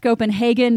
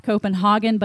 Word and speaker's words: Copenhagen: By the way, it is pronounced Copenhagen, not Copenhagen, but that's only if you believe the Danish embassy.